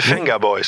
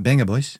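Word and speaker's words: Vengaboys: Vandaar de hit van de Benga Boys. – De Venga Boys. – Nee, de Benga Boys.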